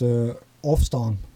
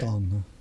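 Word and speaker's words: Ofstân: How would you pronounce that? – That's actually in Frisian. I don't speak Frisian, but it's Ofstan. – Okay. Ofstan.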